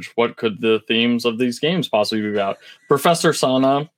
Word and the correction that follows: Sada: I'm going to make sure I got her name right, because I feel bad. Sada. – Right. – Not Sana.